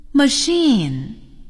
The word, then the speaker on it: machine: 最后，字母组合 CH 还可以发 SH。我们来听一听。 Machine, a washing machine. SH, chef. He's a chef. Chicago. He lives in Chicago.